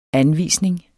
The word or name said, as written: anvisning